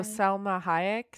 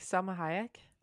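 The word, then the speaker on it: Salma Hayek: Salima Hayek or something. – Oh, Selma Hayek. Selma Hayek.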